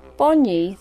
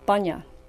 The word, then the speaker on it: Bonyi: Bonyi, the native name for the pine, Araucaria bidwilli, has been wrongly accepted and pronounced Bunya.